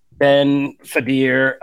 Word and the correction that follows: Kingsley Ben-Adir: I always kind of like mess up his name. I don't want to butcher his name. Ben Mendelsohn, Kingsley Ben-Adir. Kingsley Ben-Adir, who plays the villain in it.